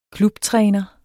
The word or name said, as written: klubtræner